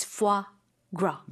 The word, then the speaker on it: foie gras: It's spelled Foie, and then gras is actually Gras, but that S is silent. It's foie gras.